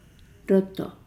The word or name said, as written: lodo